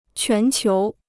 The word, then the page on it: 全球: quán qiú